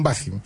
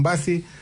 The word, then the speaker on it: Bassi: Mi apellido italiano se escribe con B larga, con dos S ahí, para que lo busquen, Giovanni Calderón Bassi, para que me sigan en redes sociales.